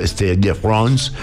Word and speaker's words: Stade de France: Pues, señores, acaba de batir un nuevo récord y fueron 6 fechas en París, en el estadio Stade de France, en París.